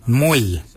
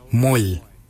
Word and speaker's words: mooly: Warago is spelled W-O-R-R-A-G-O-L. Warago. Warago is your upper mouth. For your nose, we say moil. Moil is your nose. Moil is spelled M-O-O-L-Y. Moil.